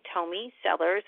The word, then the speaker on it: Tomey Sellars: Well, really, you can just search me under my name, Tomey Sellars, and I'll spell that. It's T-O-M like Tom, E-Y, and then S as in Sam, E-L-L-A-R-S.